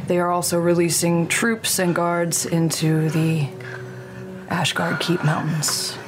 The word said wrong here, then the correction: Ashkeeper: The Ashkeeper Mountains. I said it correctly in game.